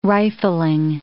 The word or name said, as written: rifling